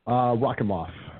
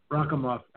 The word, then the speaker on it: Rakhimov: Rakhimov. – Rakhimov.